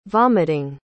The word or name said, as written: vomiting